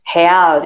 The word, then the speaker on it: real: real itu juga uh, eh, uh, eh, heal. Nah, itu bacanya heal karena R-nya jadi H.